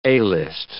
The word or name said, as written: A-list